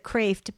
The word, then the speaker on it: Kreeft: And he spells it K-R-E-E-F-T. Okay, he pronounces it Kraft, but it's spelled like Kraft.